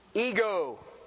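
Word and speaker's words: Ego (ἐγώ): Ego.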